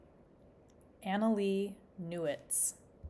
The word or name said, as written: Annalee Newitz